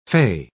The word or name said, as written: fey